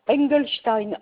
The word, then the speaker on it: Engelstein: Engelstein, E-N-G-E-L-S. – Oui. – T-E-I-N.